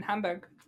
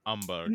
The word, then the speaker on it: Hamburg: I mean, we could bring him when we go in Hamburg. – Please say it correctly when you're trying. – Hamburg. – No, I can't. – Hamburg. – It's not Hamburg. You can only say that when you're a local.